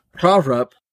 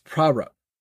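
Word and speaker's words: Pahrump: And that's actually the pronunciation of it. I found that out from a truck driver I talked to once on the phone. Prahrup, Nevada. Do you know? – I thought it was Prahrup. – It's actually pronounced Prahrup. – I believe you. – That's how the locals actually pronounce it.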